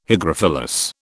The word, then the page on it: hygrophilous: British English